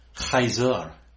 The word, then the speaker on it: Caesar: And they scream out, we have no king but Caesar. That's how we pronounce it in the English. In the Latin, they would have said, Caesar, because it's a hard k sound, not a soft s.